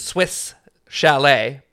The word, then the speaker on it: Swiss Chalet: Yeah, this guy, I mean, I'm gonna Canada reference, but he thought it was Swish Chalet. – Yeah, because that's how you say it. Ago. – No, it's how do you spell it? – Chalet. Well, no, I understand what he's saying because it is Swiss Chalet, but Swiss Chalet.